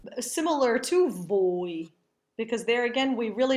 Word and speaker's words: voi: Esattamente, anche voi è un caso molto simile a mia, è un caso analogo. Voi, O e I sono separate, ma non vanno pronunciate distintamente. La O è abbastanza lunga e accentata. – Yes, voi is analogous to mia. The O and the I are pronounced distinctly, and the O is rather long and accented.